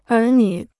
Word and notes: 儿女: ér nǚ

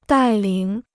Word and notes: 带领: dài lǐng

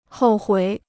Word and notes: 后悔: hòu huǐ

